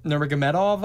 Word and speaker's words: Nurmagomedov: But also, I don't really like Khabib Nurmagomedov, I believe is the way you pronounce his last name.